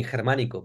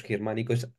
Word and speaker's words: Xermanico: So in my village, it's really common that people call me Germanico or something like that, and I choose my name in Galician to sign my comic books. That's simple, and it's called Germanico. Anyway, I don't get offended when people call me with a mispronounced name, or they choose to call me Germanico, because Germanico is also my name, so it's okay.